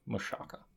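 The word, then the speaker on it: Michalka: Mashalka? – I don't know. I remember on Disney Channel, they pronounce it Mashaka.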